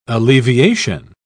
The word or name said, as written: alleviation